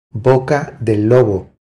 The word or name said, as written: Boca del lobo